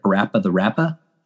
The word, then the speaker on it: Parappa the Rapper: No, what's Parappa the Rapper? Did I say that right? Parappa the Rapper? – Parappa the Rapper.